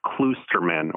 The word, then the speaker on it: Kloosterman: It's spelled K-L-O-O-S-T-E-R-M-A-N, and it's pronounced Klusterman, or at least that's how we sort of say it. It's a Dutch last name.